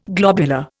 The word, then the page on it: globular: Indian